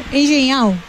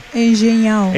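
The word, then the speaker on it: Engenhão: Todo listo en el Encheñao. Está bien dicho, ¿no? no ¿Cómo se dice? – Engenhao. – ¿Cuánto? – Engenhao.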